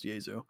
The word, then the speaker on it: Jesu: And I also looked at their Wikipedia, which is why I know it's pronounced Jesu.